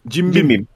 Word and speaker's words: Jim Beam: Nasıl okunuyor bu? Jim Beam mi? – Jim Beam.